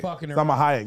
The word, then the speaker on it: Salma Hayek: Salma Hayek, yeah.